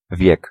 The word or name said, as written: age